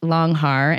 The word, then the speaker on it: Langhaar: And am I pronouncing that right, Langhaar? – You are, yes. I think okay, the Germans may say it a little bit differently, but I don't have their accent, so.